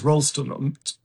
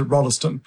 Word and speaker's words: Rolston: So I hope my middle name is Rolston, they pronounce it Rolleston.